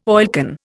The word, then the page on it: oilcan: Indian